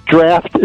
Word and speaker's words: draught: But it's spelled D-R-A-U-G-H-T, huh? – Right, but it's pronounced D-R-A-F-T.